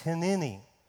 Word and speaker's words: hineni: One way of pronouncing that is hineni, and the way we're pronouncing it is hineni. Hineni. Just go ahead and wiggle your lips and it'll come out.